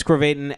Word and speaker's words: Krovatin: You can follow me on Instagram, Chris Krovatin at Krovatinist.